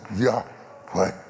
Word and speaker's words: Yahweh: His name, Yahweh, properly pronounced, is the same sound of breathing. Yahweh means you have to inhale, Yahweh. Yahweh. You cannot say it without inhaling and exhaling.